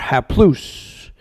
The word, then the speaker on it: haplous: Well, what he's saying is in the Greek word, you can write this down in your notes if you want to, it's spelled H-A-P-L-O-U-S or haplous, if you want to pronounce it in Greek.